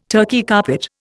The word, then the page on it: Turkey carpet: Indian